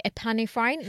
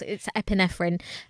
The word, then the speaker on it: epinephrine: I used to say epinephrine in my head, which is like not how it's said. It's epinephrine.